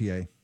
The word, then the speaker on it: Ye: It's ye. I mean, yay. – Yeah, see? I can't ever figure it out. – I know it's spelled ye, but it's yay.